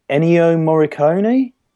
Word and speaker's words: Ennio Morricone: And I sort of just got obsessed with, I always pronounce his name wrong. I think it's pronounced Ennio Morricone.